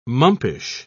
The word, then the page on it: mumpish: British English